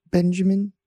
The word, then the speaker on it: Benjamin: Yes, my name is Benjamin. That's spelled B-E-N, Benjamin.